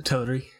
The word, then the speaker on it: Teleri: Killing the, is that the Teleri? Is that how you say it? – Yeah, the Teleri.